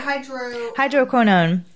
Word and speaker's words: Hydroquinone: How do you say it? Hydroquinone. There we go. – There you go.